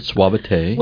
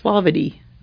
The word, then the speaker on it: suavity: Well, it's pronounced suavity. But I think it ought to be. – Oh. – I mean, it's spelled suavity, but I think it suavite. – Ought to be pronounced suavite.